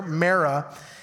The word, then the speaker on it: Marah: That's how you pronounce the word here, Marah.